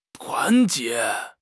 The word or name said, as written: 团结